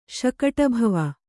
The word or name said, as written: ಶಕಟಭವ